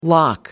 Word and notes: lough: American English

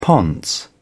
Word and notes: ponce: British English

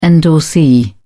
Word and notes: endorsee: British English

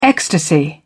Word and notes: ecstasy: British English

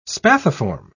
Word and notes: spathiform: British English

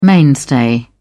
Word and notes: mainstay: British English

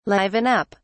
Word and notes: liven up: British English

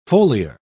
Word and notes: foliar: British English

